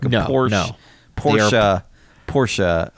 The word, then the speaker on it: Porsche: They're like a Porsche. I think is the actual pronunciation. – Oh, Porsche. Sorry. I'm sorry. I'm too rich for pronunciation nowadays. – Yeah, you've got more better things to do with your time. Then pronounce that last like entirely voluntary vowel sound in Porsche.